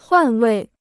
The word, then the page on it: 换位: huàn wèi